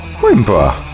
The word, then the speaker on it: Coimbra: Coimbra.